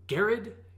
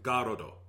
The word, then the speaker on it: Garrod: And then Garod is the same way, but more subtly. Because, so his name is spelled G-A-R-R-O-D, and you look at him like, Garod? Garod? Like, those are workable, but they just don't quite fit to me.